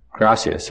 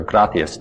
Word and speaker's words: gracias: Gracias or gracias, depending on which part of Spanish-speaking world you're from.